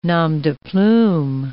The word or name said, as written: nom de plume